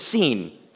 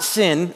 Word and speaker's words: Sin: At the Lord's command, the whole community of Israel left the wilderness of Sin. It's not sin, it's pronounced Sin. It's a really convenient place they were in, but it really has nothing to do with sin, although it does fit.